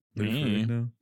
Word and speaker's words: Ferrigno: Ferrigno? Is that it? – Lou Ferrigno. – I don't know who that is. – I don't either. – Actor. – Okay. Lou Ferrigno.